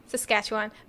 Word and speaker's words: Saskatchewan: He was born in 1906 in Saskatchewan, Canada. I hope I said that right. – I think you did. It sounds okay. – Right. – Saskatchewan.